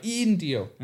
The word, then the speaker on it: Indio: I, with that long I, Indio.